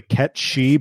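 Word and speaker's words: Cait Sith: So I think that means we got to start with Kate Sith, or the character's name is actually Ket Shi, but it's spelled Kate Sith.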